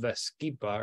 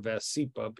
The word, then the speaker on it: Vascepa: And recently, a particular brand called Vascepa, or Vascepa, people pronounce it different ways, was associated with a slightly higher rate of atrial fibrillation by taking 4 grams a day to lower your triglycerides.